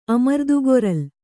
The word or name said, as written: ಅಮರ್ದುಗೊರಲ್